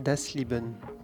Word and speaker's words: Das Leben: On t'écoute le premier morceau de ce disque qui s'appelle Das Leben. C'est bon, c'est bien prononcé ? – Oui, parfait.